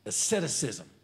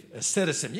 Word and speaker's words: asceticism: And the last one, excessive asceticism. Oh, I said that right. Excessive asceticism.